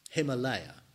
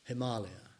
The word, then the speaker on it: Himalaya: Him means snow. Alaya means place or basis of the snow. Himalaya, as we mispronounce it. Himalaya, the place of the snow.